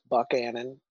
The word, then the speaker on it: Buchanan: Buchanan, the town of Buchanan is pronounced Buckanan.